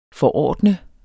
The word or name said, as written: forordne